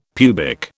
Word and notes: pubic: British English

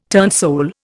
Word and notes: turnsole: Indian

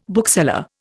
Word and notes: bookseller: Indian

